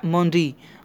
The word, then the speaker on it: Mendy: que se pronuncia Mondi, Mondi con, la D así rara, pero bueno.